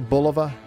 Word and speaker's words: Bulova: On this day in 1987, although the Bulova Watch Company, am I saying that correctly? Bulova? – Yes, you are.